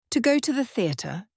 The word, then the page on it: to go to the teather: British English